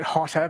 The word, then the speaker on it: HOTA: And even though we're pronouncing it Hotter, because that's the fun way to do it, it's spelt H-O-T-A, isn't it? – Yes.